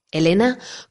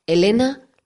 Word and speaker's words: Helena: Pues mira, me puedes encontrar en Twitter en arroba Elena Casares, Elena con H, y en las redes sociales Facebook y LinkedIn con mi nombre. Recuerda que mi nombre se escribe con H. Elena López Casares, apellido compuesto, y el último, Pertusa, que no por ello el menos importante.